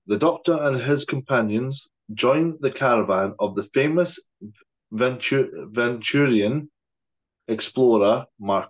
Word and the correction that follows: Venetian: I think it's pronounced Venetian. – Venetian. Oh, yeah.